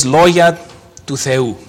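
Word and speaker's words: λόγια του Θεού: Now, the oracles of God is a translation of the Greek phrase logia του Θεού. And I pronounce that correctly because I'm Greek.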